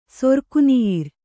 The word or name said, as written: ಸೊರ್ಕುನಿೀರ್